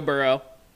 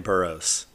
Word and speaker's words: Burrow: I was thinking of my friend Wilson, who pronounces his last name Burrows. So shout out Wilson, who knows that it's wrong and still okay. – Says it wrong.